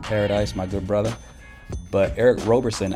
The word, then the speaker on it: Eric Roberson: One of the standout songs on that mix is a tune called Lessons by Philadelphia soul singer Eric Roberson. At first glance, it looks like Robertson, but that's not how you say his name, ladies and gentlemen. It's Eric Roberson.